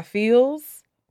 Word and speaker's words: Pheelz: So the third one is a song called Finesse by Feels. It's spelled P-H-E-E-L-Z.